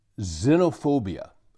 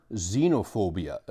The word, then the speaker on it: xenophobia: Uh, this word, you hear it now and then, but here's the word xenophobia or xenophobia. Apparently, there are two groups, there are two ga- camps on this word in regard to the proper pronunciation, xenophobia or xenophobia, X E N O, and then phobia.